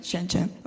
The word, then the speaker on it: Shenzhen: Shenzhen, am I pronouncing right? Shenzhen, okay.